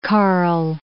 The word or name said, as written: Karle